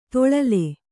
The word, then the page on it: ತೊಳಲೆ: toḷale